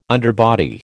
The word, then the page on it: underbody: American English